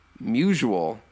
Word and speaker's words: Musial: Musial? – Mutual.